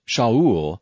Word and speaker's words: Shaul: The name Saul, from the Hebrew word pronounced Shaul, means asked.